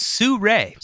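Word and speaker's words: Sirui: So what I opted for at the time, this was in 2017, I bought the, I think it's pronounced Siru. – It's Suray. – Suray. Oh, I'm glad you're here to help me with that. Suray. Okay. Yeah. It's S-I-R-U-I, which I would never have expected was Suray.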